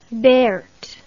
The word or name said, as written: Berthe